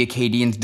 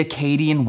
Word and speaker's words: Acadian: The Acadian French pronunciation doesn't emphasize the A at the beginning of the word, and the D probably sounds like a J to English ears.